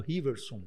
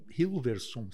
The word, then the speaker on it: Hilversum: Então eu, eu, eu começo a escutar, a primeira rádio que eu escuto foi a Rádio Riversum, da Holanda. Riversum fica bem, Hilversum se escreve, uhum.